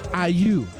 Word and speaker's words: Ayew: Y el delantero estrella de la selección de Ghana es Ayew.